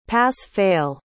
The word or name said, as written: pass-fail